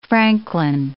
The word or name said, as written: francolin